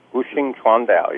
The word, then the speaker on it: Wuxing Chuandao: But how did you decide to, you said you're writing a new book, and it's, let me see if I can say this properly, Wuxing Chuandao. – Wuxing Chuandao, yes.